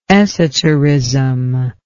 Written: esoterism

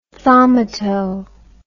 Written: thaumato

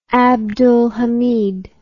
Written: abdul hamid ii

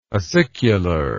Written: acicularly

